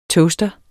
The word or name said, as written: TOASTERE